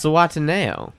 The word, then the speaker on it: Zihuatanejo: But it's still pronounced Zihuatanejo.